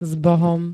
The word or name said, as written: zbohom